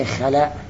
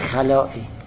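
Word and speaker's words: خلاء: من در تمام حرفایی که می زدم تلفظ می کردم به خلع، تلفظ به خلع درست نیست مگر اونجایی که بخوام وقف کنیم. تلفظ درستش خلاعه، چون در فارسی خلاع معنای خوبی نداره، ما غالبا خلع تلفظ می کنیم، ولی خلا درست نیست، خلا درسته.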